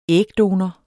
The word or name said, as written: ægdonor